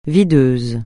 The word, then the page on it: videuse: francais